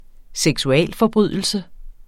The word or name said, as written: seksualforbrydelse